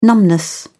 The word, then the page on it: numbness: British English